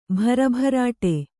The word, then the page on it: ಭರಭರಾಟಿ: bhara bharāṭi